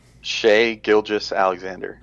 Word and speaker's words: Shai Gilgeous-Alexander: How would you pronounce the guy's name who has a hyphen in his last name? – Shai Gilgeous-Alexander. – That's what I would say. And then I heard someone on a video say Gilgeous, and I was like, huh. – No, I think that guy just was over-pronunciating. I don't think that's for real.